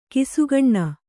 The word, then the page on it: ಘಸಕ್ಕನೆ: ghasakkane